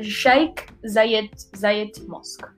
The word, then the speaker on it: Sheikh Zayed Mosque: I'm actually happy because it's really difficult to pronounce and I was already worried I was going to pronounce it wrong. But you did right. It's the Sheikh Zayed Mosque.